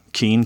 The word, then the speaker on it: Keane: Um, last episode, I finished talking about my last name spelling Keane K E A N E.